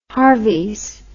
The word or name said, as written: Harveys